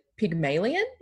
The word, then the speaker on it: Pygmalion: Again, this like ties into the sex bot in kind of the way that sex bots are created, because, and I'm going to pronounce this incorrectly, Pygmalion, is that how you pronounce that? – Mm-hmm.